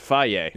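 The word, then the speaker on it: Fage: It's not Faye? – It's Faye. – It's on the side, yeah. Pronounced Faye.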